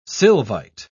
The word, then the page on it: sylvite: British English